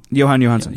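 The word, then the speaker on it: Jóhann Jóhannsson: How do you say it? Johan? – Johan Johansson. – Johan Johansson.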